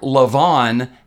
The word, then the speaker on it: Levon: He was Mark Levon Helm, but they wouldn't.